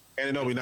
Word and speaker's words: Anunoby: Is it Anunoby? Is that how you say his name? – Anunoby.